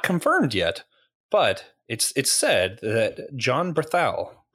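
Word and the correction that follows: Bernthal: how do you say that? The Punisher. – Berenthal.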